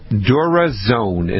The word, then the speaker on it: DuraZone: It's called DuraZone, and it's spelled DuraZ. O-N-E, DuraZone, and it's made by Bayer, the people that make the aspirin, Bayer.